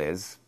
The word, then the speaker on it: is: The trouble is, when you pronounce it, you cannot add an extra syllable. You cannot go idze, idze, like that. It must be smooth. Zzz, like the first letter in zoo or zebra.